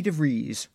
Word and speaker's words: DeVries: Our good friend, Patreon supporter, and episode 17-inch guest, Vicky DeVries, rhymes with cheese,